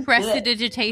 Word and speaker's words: prestidigitation: So one of the things that I can do, one of my cantrips, and I'm going to mispronounce this, it's the prestidigitation. – Prestidigitation.